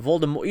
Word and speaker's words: Voldemort: Turns out it's supposed to be a French pronunciation. Voldemort. You just don't pronounce the T. And she says Voldemort.